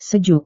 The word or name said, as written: sejuk